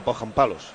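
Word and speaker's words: Pohjanpalo: Poggianpalo se llama así. – Poggianpalo. – Poggianpalo se escribe. – Ah, Poggianpalos. – O sea, Poggianpalo. – Sí, señor. Así se pronuncia.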